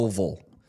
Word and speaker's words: Louisville: And I was like, you mean Louisville? You know, like, and I'm from Kentucky, so that's why I know how to pronounce Louisville. – Louisville.